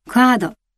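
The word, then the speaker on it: curd: 口をあまり開けずにクアードと発音します。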